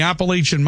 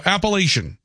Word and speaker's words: Appalachian: Text writes, coming from somebody who grew up in the Appalachian Mountains, you are saying it correctly. So Appalachian, that's how it said.